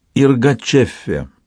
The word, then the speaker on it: Иргачеффе: «Иргачеффе» — примечание, сорт эфиопского кофе, конец примечания. Старший кузен, вдоволь напутешествовавшийся, научил ее произносить это слово с мягким «и», грассирующим «р», твердым «ч» и легким придыханием в конце.